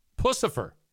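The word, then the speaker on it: Puscifer: Pucifer.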